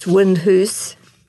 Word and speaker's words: Windhouse: Windhouse, pronounced Windhoose, is reportedly the most haunted house in Shetland.